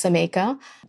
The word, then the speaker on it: Xaymaca: It's spelled X-A-Y-M-A-C-A, but it's pronounced Zameca.